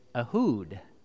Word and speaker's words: Ehud: And so to today, we are going to study the life of Ehud, and we're going to pronounce his name correctly, and it is Ehud. Isn't that strange? Ehud. That is how we say his name.